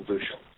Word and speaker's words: bushel: I accentuate the long U in bushel. That's just my colloquial expression.